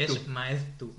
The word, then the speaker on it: Maeztu: Vale, y después también tenemos aquí a Gaby Maestu. ¿Lo digo bien? ¿Maestu? Nunca sé si es Maeztu o Maestu. Maestu. – ¿cómo se pronuncia eso? Maeztu. Es Maestu. Maestu.